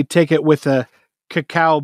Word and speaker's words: cacao: It's pronounced cocoa when it's spelled like cocoa, but if it's spelled like dyslexic cocoa, it's cacao.